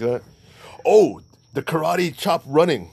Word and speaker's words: karate: When I say karate, it's karate. So the correct way to say karate is karate.